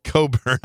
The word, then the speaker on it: Cockburn: Kofi Coburn, spelled C-O-C-K Burn, really. – Wow. – It looks like it should be pronounced Cockburn, but they call it Coburn.